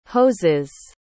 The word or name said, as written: hoses